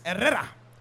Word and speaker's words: Herrera: Okay, I've struggled just a little bit with my last name because my last name's Herrera. My last name's Herrera. And now, the more proper pronunciation of my last name is Herrera. and you roll the R's.